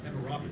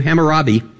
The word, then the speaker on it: Hammurabi: How do you say that? Hammurabi? – Hammurabi. – Thank you. Hammurabi.